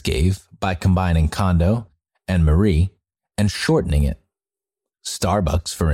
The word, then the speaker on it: Marie: Kondo's first name, Marie, is actually pronounced Marie, but to the English speakers, it just looks like the Western name Marie and is easy to pronounce.